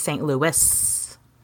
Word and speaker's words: St. Louis: And overwhelmingly everyone was like, it's St. Louis. You pronounce the S.